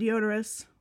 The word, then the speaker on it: Diodorus: How do you say it? – Deodorous. – That's what it looks like. – I know. I'm sure that's probably how you say it. It just sounds like deodorant. That's it does. I would think it would be like Diodorus. – Diodorus? – Yeah.